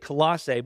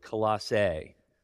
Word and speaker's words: Colossae: You can pronounce it Colossae, Colossae, Colossae, and I've even heard somebody say Colossa. I'm going to stick with Colossae. If I go in and out, don't judge me, okay? But it's okay to pronounce it any of those ways.